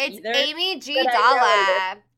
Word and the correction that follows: amygdala: Amy G. Dalla. So, Amygdala? – Amygdala!